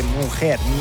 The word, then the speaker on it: mujer: L'Afro Beat di Icy Sub-Zero, la sua mujer, così si pronuncia in spagnolo correttamente, mujer, vabbè, basta.